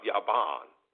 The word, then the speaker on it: Javan: but it is really pronounced Yah, is pronounced Yaban.